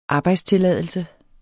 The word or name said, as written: arbejdstilladelse